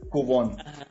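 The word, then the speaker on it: Koivun: Yo le digo Jackson Cubbon, tú lo pronuncias Cubbon. ¿Cuál es Lo googleé, Cubón. – Cubón.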